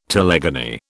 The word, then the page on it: telegony: British English